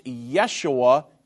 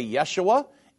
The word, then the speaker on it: Yeshua: And that's why Jesus is pronounced Yeshua in Hebrew, but it's the exact same word. So, if you're Hebrew, you would say Yeshua. If you're English, you would say Jesus.